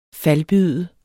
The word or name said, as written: falbyde